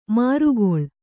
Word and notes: ಮಾರುಗೊಳ್: mārugoḷ

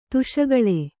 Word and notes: ದುಷ್ಕಾಳ: duṣkāḷa

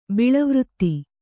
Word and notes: ಬೀಳುವ್ರಿತ್ತಿ: kannada

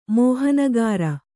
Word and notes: ಮೋಹನಗಾರ: mōhanagāra

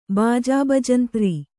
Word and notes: ಬಾಜಾಬಜಂತ್ರಿ: Kannada

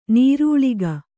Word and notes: ನೀರಳ್ಕೆ: Kannada

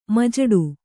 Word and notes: ಮಜಡು: kannada

